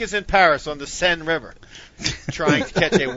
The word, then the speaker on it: Seine: The Seine.